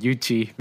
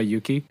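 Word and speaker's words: Yuqi: I think in a previous episode I pronounced it Yuchi because that's how it'd be in Chinese, but in this case we'll go by Yuki.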